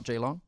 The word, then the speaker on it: Geelong: Ben and Reed from Geelong, Australia. They're fellow podcasters. – Hey, Geelong. Just out of Melbourne. – Is that how you say it? Geelong? – Yeah. – Yeah. – Not Geelong? – No, Geelong.